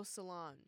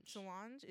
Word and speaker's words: Solange: Like, I don't know how you would classify it, but he's singing a lot and it's like all these funk and Solange, the Beyonce's younger sister. – Oh, Solange. – Solange, is it? Okay. – Solange.